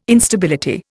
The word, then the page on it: Instability: Indian